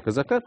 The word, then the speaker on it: Козака: Скажем, многие цитировали заявление министра регионального развития Дмитрия Козака, или как он сам просит ставить ударение в его фамилии, Дмитрия Козака. Так вот, он вроде и сказал, что там...